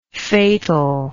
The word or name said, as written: fatalness